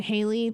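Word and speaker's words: Hailey: And I am Jillian, so you can find me on social as at Jillian Haley. That's H-A-I-L-E-Y, and Jillian spelled with a J.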